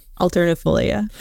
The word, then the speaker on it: alternifolia: It's a tea Alternifolia. – Tree. Alternifolia.